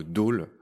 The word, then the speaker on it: dhole: on va commencer par le dôle. Euh, le dôle, c'est un chien sauvage d'Asie, je vais le dire comme ça. Euh, son nom s'écrit D-H-O-L-E.